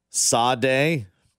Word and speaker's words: Sade: It's pronounced Sade, Bob. Bro from the 913. It's Sade. From the 720, Sade is pronounced Sade. Bob, you old enough to know that. Thanks, Coach. – Mm-hmm. – Also, it's spelled Sade. – Yeah, S-A-D-E, Sade.